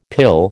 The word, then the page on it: pill: American English